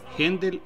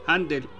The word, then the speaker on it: Händel: Para no enredarnos demasiado, el apellido se pronuncia Händel en, en alemán y Handel en inglés.